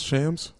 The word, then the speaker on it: Shams: Shams? – Shams.